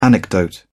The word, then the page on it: anecdote: British English